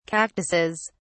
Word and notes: cactuses: British English

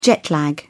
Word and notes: jet lag: British English

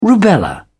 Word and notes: rubella: British English

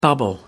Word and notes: bubble up: American English

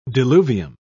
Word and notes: diluvium: British English

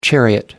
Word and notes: chariot: American English

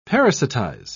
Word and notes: parasitize: British English

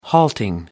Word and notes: halting: American English

